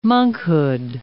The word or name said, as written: monkhood